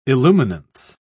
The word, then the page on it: illuminance: British English